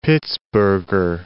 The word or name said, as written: Pittsburgher